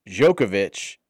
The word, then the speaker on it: Djokovic: Djokovic is three syllables.